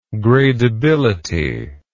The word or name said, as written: gradeability